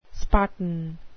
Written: spartan

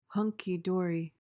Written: hunky-dory

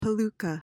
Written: palooka

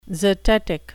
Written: zetetic

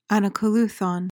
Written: anacoluthon

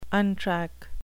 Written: untrack

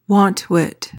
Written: wantwit

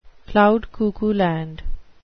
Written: cloud-cuckoo-land

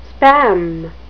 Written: spam

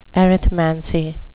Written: arithmancy

